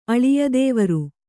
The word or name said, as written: ಅಳಿಯದೇವರು